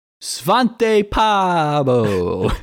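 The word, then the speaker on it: Svante Pääbo: Svante Paabo. – I think you pronounced it correctly. That sounded pretty correct to me.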